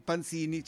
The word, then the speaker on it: Panzini: E inizio con l'oroscopo di Panzini, c'è l'accento sopra la O, no l'ultima, è la penultima O.